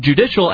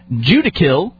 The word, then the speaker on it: judicial: There were a few who didn't know that our country was connected to Canada, and some were pronouncing the word judicial as judicil.